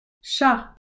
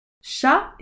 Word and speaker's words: chat: Chat, cat. souris, mouse, lapin, rabbit, chèvre, goat, mouton, sheep, vache, cow, chien, chat, souris, lapin, chèvre, mouton, vache. Chien is spelled C H I E N. Chien. Chat is spelled C H A T.